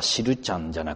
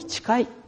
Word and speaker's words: 誓: でも次女の名前は読み方は知るちゃんじゃなくて、ましてや知らすちゃんでもなくて、誓いと読むようにしたんですね。これも以前にも言ったことがありますけれども、最初は遠い誓いの誓いにしようと思ったんです。